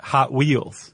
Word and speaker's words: Hot Wheels: That's how they pronounce it, Hot Wheels. – Oh, instead of Hot Wheels? – Yeah. The emphasis is on the hot.